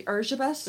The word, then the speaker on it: Erzsébet: And also her name is actually Erzsébet, which is the Hungarian pronunciation, but it's Elizabeth in English when you translate it back.